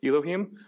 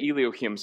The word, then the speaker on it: Elohim: Man, I hate when I mispronounce that word. Elohim? Thanks. I say Elohim sometimes, don't I? And it's wrong. Thank you. Okay. Nathan just rebuked me.